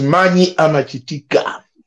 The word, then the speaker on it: mani amachitika: You guys, maybe who don't know how to pronounce our language, make sure when you're saying, don't say mani amachitika. No. Mani amachitika. Make sure you put power. That's where the meaning of the word comes from.